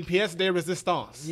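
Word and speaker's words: pièce de résistance: That'll be the pièce de resistance.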